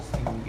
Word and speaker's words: Osteen: The Osteen or whatever you however you pronounce it. Osteen, Joel Osteen, yeah.